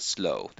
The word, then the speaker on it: sloe: Men det stavas ju S-L-O-E, men uttalas slow, det vill säga på samma sätt som slow, som är långsam, som också är det, eh, det sätt som man stavar den här drinken, S-L-O-W.